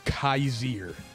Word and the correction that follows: Kazir: I think it's actually pronounced Kazir, by the way.